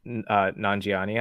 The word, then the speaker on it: Nanjiani: Kumail Nanjiani, I think is how you say his last name.